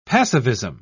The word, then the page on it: passivism: British English